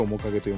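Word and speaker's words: 母影: 泣きたくなるほど嬉しい日々に」などがございますそして 1 月の 29 日に新潮社より刊行されました「面影」がですね、はい、母の影」と書いて「面影」と読む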